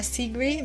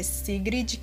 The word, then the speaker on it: Sigrid: Hoje eu vou indicar a Sigrid, tipo, fala Sigrid, mas na verdade é Sigrid, que se escreve, né? S-I-G-R-I-D.